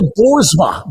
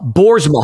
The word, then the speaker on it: Boersma: Bill Boersma. Boersma. How do you spell that? – B-O-E-R-S-M-A. B-O-E-R-S-M-A.